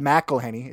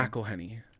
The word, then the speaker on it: McElhenney: Rob McElhenney is how you pronounce his name. – McElhenney.